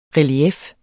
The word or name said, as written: relief